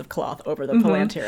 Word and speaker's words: Palantir: So since we're talking so much about the Seeing Stones, how do you say that again? – Palantir? – Palantir. – P-A-L-A-N-T-I-R.